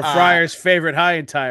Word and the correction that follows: Hentai: or however you pronounce it. – Hentai, yeah.